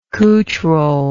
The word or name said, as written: couch roll